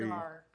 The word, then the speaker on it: Navarre: One of the districts, and I've never been here, so I hope I pronounce it right, Navarre Beach? Don't know where that is. – Navarre. – Navarre Beach. Okay, I'll have to go.